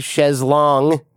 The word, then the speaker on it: chaise longue: You could be sitting on some super fancy chaise longue.